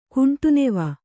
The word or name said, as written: ಕುಂಟುನೆವ